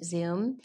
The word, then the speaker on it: Zume: It was spelled Zume Pizza, but we pronounced it Zoom.